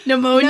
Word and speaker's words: pneumonia: For example, failure to recognize ammonia. What did I just say it wrong? okay. I'm editing this one, I think. yeah, I had to edit the toilet one. This is yours. Ammonia? Pneumonia? – Pneumonia.